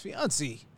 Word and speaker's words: fiancé: You're just Americanizing fiancé. – It. It's fiancé. – Fiancé. Yeah, oh, that's it. Hey, how about that? – He just rebranded a French word by saying it incorrectly in French. Fiancé.